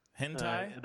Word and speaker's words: hentai: Yeah, there's a lot of a couple people saying they're watching Hensei. Or is it Hensei? How do you say that word? – Hentai. – Hentai. Like the Japanese hentai. – Anime. – Oh, hentai.